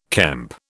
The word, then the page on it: kemp: British English